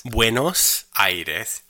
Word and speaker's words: Buenos Aires: Now let's try the pronunciation again. Buenos Aires.